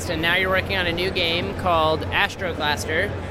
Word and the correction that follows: Astrogaster: Nobody really knows how to pronounce it, but I think it's Astrogaster. – Okay. Astrogaster. Got it. Okay. I put an L in there, I think, that's not there.